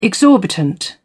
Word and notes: exorbitant: British English

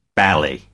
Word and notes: bally: American English

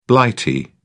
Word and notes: blighty: British English

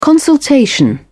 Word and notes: consultation: British English

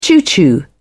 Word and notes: choo-choo: British English

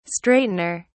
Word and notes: straightener: British English

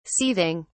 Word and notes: seething: British English

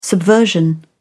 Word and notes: subversion: British English

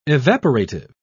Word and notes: evaporative: British English

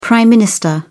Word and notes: Prime Minister: British English

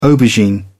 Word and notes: aubergine: British English

